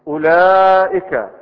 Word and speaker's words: أولئك: أولئك ها هل ننطقها أولئك؟ لا أولئك، والواو موجودة بين الهمزة واللام.